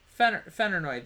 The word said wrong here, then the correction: Feyenoord: the Dutch team. Did I not say that correctly? – Feyenoord. – Okay, well, I was close.